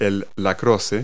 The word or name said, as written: lacrosse